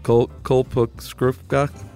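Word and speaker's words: Kalpavriksha: Second, the coconut tree is just one of the trees considered a, how do you pronounce it again? Kulpa Vrishka? – Uh-huh. There you go. You nailed it.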